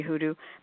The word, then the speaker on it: hoodoo: Hurston explains white people pronounce it voodoo. And what she meant by that was a double signification. It's pronounced with a V sound, and it should be hoodoo. But she's also saying they pronounce it as from on high. Well, you know, you colored people are practicing voodoo. But she's fighting back, saying, no, they pronounce it voodoo, but it's hoodoo.